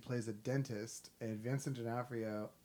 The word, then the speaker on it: D'Onofrio: Is that how you pronounce his last name? – D'Onofrio. – Yeah.